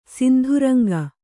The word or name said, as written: ಸಿಂಧುರಂಗ